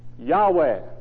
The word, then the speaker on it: Yahweh: It could have been Yay or Ye or Yah. Who knows? But let's go with Yah for the time being. So you have Y-A-H to begin with. Let's fill in some more vowel sounds. Place an E between the W and the H, the last two letters. Now what do you have? Yahweh.